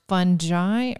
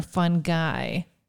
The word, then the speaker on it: fungi: Well, first things first, I know the most important question everyone needs to know, is it pronounced fungi or fun guy? – It can be pronounced either way. You hear it a lot. Even among the experts, they do it either way.